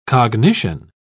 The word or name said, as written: cognition